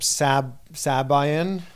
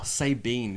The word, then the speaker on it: Sabian: Sabine is my favorite one. – Sabine. – B-E-A-N. Sabine.